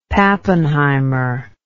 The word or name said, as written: pappenheimer